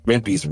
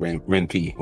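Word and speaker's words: Ren'Py: I always say Renpy because I think it's like a combination of Ren and Python, which is the language it uses.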